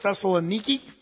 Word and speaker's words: Thessaloniki: The place of Thessalonica, sometimes or modern times pronounce it Thessaloniki or Saloniki in times past.